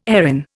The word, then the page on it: Erin: Indian